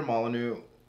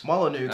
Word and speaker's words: Molyneux: And we've talked about this too, is that, like, the way that Peter Molyneux... – Or Molyneux. – Molyneux, as is the proper pronunciation of his name.